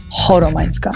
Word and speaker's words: Choromańska: I po prostu później chodziłam do tych sędziów i mówiłam im, słuchajcie, moje nazwisko czyta się przez H, Choromańska.